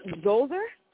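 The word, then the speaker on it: Djoser: I believe it is pronounced Zoser. – Spell it for me. – D-J-O-Z or S-E-R.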